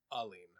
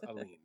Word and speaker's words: Aline: I knew I was going to say that wrong. Mm-hmm. And I wrote it, and I looked at it, and then I still put the R in. There's no R. It's Aline.